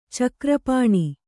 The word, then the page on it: ಚಕ್ರಪಾಣಿ: Kannada